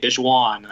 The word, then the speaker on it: DaJuan Gordon: Uh, I, I, think I think it's a hard a hard J. Dishwan.